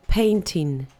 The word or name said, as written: painting